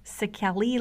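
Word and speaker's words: Székely: he also proudly claims his Szekely lineage, forgive me for the pronunciations, that's spelled S-Z-E-K-E-L-Y, and it's a specifically Hungarian ethnic group.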